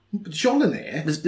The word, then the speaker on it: Mjolnir: That's like, it's Mjolnir. – What? – Jolinir? – The M is silent.